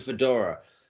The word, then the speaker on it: Nymphadora: Nymphadora Tonks. Is that correct? – How you say it? – Correct. – Yes, Nymphadora. Almost said something else.